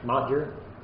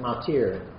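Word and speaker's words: Motyer: His name is spelled M-O-T-Y-E-R. I've always thought it as Modger. But Lannan tells me he heard British people pronounce it Matir. So the Y is treated as an I suppose, and it comes out Matir.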